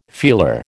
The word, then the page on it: feeler: American English